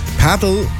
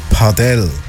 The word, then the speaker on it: Padel: Paddle oder richtig ausgesprochen Padel, habe ich auch lernen, wo man auf einem kleineren Tennisfeld mit so mittelgrossen Schläger wie eine verkürzte Art von Tennis spielt.